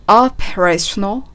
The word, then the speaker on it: operational: オペレーショナルというカタカナ読みするとそういう単語なんですが、 operational と発音されていますね。最初の O を少し伸ばし目、そして最後のなるのところをなるではなくて、operational, operational オペレーションのように発音すると英語らしくなります。